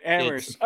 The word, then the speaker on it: Amherst: Okay, Amherst. Okay, see, I didn't look at it that way because I'm used to it all being ham, and it's like, in my brain, it's like, don't pronounce ham.